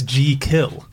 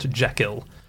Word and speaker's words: Jekyll: The first one is, we're all saying it wrong. It's G-Kill. – G-Kill. – G-Kill. If you were pronouncing it, it's J-E-E, and then kill. – G-Kill. – Yeah. – Is that what he said? Did he put a pronunciation guide in the back? – He did. – For the audiobook. – He said that in an interview. He said, let the name be pronounced as it is spelt, G-Kill, not Jekyll.